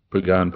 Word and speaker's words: Pagan: Pagan, probably. – P-A-G-A-N, Emilio Pagan.